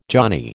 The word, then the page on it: johnny: American English